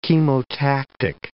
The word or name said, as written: chemotactic